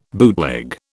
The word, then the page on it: bootleg: British English